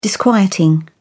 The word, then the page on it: disquieting: British English